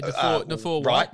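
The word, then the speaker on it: Naufahu: They call him Nathaniel, but I think it's Nafua. – Yeah, Nafua White.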